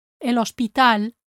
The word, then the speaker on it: hospital: When you say el alcohol and el hospital, make sure your H is silent.